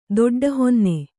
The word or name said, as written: ದೊಡ್ಡಹೊನ್ನೆ